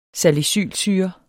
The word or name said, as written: salicylsyre